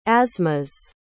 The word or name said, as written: asthmas